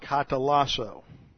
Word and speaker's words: katalasso: First of all, you have the verb katalasso. K-A-T-A-L-A-S-S-O. Long O. Katalasso.